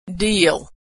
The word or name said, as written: deal